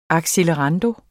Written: accelerando

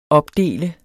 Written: opdele

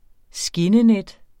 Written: skinnenet

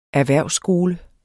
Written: erhvervsskole

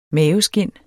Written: maveskind

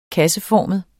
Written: kasseformet

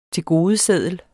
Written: tilgodeseddel

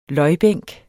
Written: løjbænk

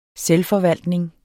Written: selvforvaltning

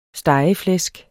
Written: stegeflæsk

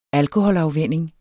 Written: alkoholafvænning

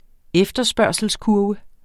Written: efterspørgselskurve

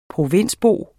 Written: provinsbo

